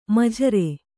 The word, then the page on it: ಮಜೂರ: majūra